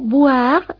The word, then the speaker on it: boire: Atención a la diferencia de pronunciación boire, bilabial, B, con voir, que es el verbo ver. Boire, beber, voir, ver. Haga bien esa distinción, porque marca una distinción de significado.